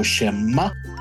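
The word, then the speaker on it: Shimá: So Shema, the emphasis on the ah.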